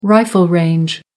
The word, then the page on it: rifle range: American English